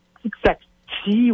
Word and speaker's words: Tixati: OK, T-I-X-A-T-I, je pense que c'est T-I-X-A-T-I. Va sur Google, là, je l'ai peut-être mal prononcé. – Tixacti? – Tixacti, ouais, ça ressemble à taxi, mais c'est pas taxi, tu sais.